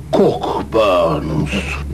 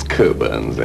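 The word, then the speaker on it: Cockburn's: Cockburns. – Not quite. It's Coburns, actually. – No, we don't pronounce the C-K.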